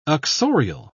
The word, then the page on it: uxorial: British English